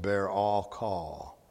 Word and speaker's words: berakah: It's spelled B-E-R-A-K-A-H. Translated blessing. It's pronounced barakah, but spelled B-E-R-A-K-A-H, is Hebrew word number 1293.